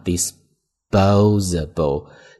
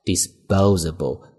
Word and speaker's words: disposable: 注意这个单词的发音啊 disposable，那 s 后面的 p 会读 b，因为这是一个清辅音浊化， disposable 这么去读。